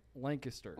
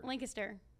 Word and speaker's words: Lancaster: Do I say it right? Lancaster. – Lancaster. – It's a cute place. – Lancaster. It's a sweet place. – There you go. – Lancaster. Yeah, you got it. You got spot on.